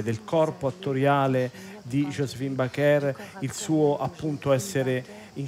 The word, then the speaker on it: Baker: Sì, la pronuncia infatti di questa attrice è Josephine Baker o Baker, e comunque anche negli Stati Uniti la chiamano con l'accento francese, alla francese.